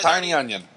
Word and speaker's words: Tynion: Tiny just like Tiny Onion.